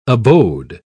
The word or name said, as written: abode